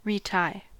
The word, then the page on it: retie: American English